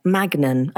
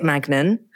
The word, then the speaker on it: Magnan: I am delighted to be joined by Sabrina Magnan. I didn't check on your name, Sabrina. Is it Magnan? – Yes, I um it's it's a French name, so it's usually pronounced Magna.